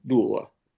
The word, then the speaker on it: doula: And the other thing about Dion, I guess we should also mention, he's become a pet doula. Am I pronouncing that properly? – Yes, you are.